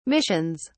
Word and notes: missions: British English

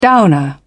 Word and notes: downer: British English

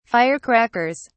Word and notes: firecrackers: British English

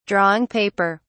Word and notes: drawing paper: British English

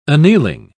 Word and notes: annealing: British English

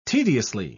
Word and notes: tediously: British English

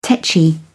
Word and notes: tetchy: British English

